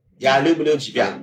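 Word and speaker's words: 呀 溜 不溜 几 遍 (я люблю тебя): 对对举个例子，俄语“ 我爱你” 呀溜不溜几遍？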